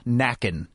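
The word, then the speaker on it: Nakken: It's Alyssa Nacken.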